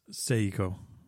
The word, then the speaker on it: Seiko: How do you pronounce Seiko? – It's Seiko. – Ah, all right. Thank you for the enlightenment. Seiko.